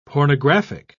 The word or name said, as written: pornographic